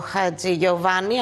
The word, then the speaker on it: Χατζηγιοβάνης: Τι έγινε, τι γίνεται με τον Τάσο Χατζηγιοβάνη, αν το λέω, αν το τονίζω σωστά. – Πολύ σωστά το τονίζεις.